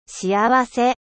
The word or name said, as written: heureux